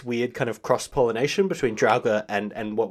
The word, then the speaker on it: Draugr: But it's interesting, and it brings me to another piece of mythology from Norse mythology, and they're called the Draugr. Again, weird pronunciation on that one. – Yeah, that looks right, I think. It sounds right for what I'm looking at, yeah.